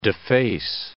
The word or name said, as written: deface